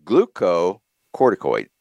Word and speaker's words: glucocorticoid: glucocorticoid.